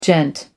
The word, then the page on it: gent: American English